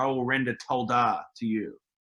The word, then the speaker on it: towdah: Yeah, well, one of the words is the word toldah, which is spelled T-O-W-D-A-H, toldah.